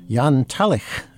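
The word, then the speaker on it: Jan Talich: Jan Tallich, I checked the pronunciation, is the lead violinist of the Tallich Quartet.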